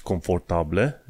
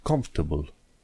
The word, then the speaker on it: comfortable: Nu zici confortabile, zici comfortable. Comfortable.